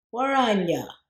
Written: Waranyja

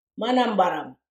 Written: manambarram

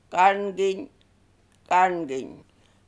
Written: Garnginy

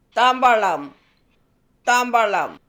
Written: Thambarlam

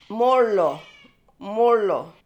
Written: Moorloo

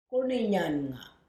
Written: Goorninyannga

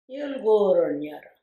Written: Yilgoowoorroon yarre

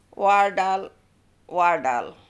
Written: Wardal

